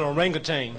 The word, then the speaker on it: orangutan: That's an orangutan. – It means man of the woods, and orangutan is exactly what it is. Orangutan. People pronounce it both ways.